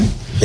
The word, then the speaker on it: Djibouti: Is it Djibouti? Is that city? – Djibouti. – I pronounce that correctly? – Djibouti. Yeah.